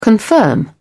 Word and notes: confirm: British English

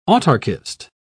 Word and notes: autarkist: British English

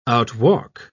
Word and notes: outwalk: British English

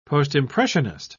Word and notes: postimpressionist: British English

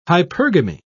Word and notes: hypergamy: British English

